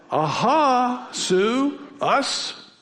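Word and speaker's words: Ahasuerus: Here's how you say it. Aha. Everyone say aha. Aha, and my wife's name is Sue. Add that. Aha, Sue, us.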